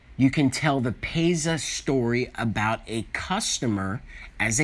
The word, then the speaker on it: PASIA: And that is, you can tell what is called the PASA. It is spelled P like Peter and then Asia, A-S-I-A.